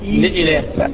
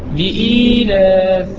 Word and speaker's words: إيلاف: لإلاف إيلاف.